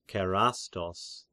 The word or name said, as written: Kerastos